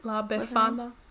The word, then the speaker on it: La Befana: La Befana.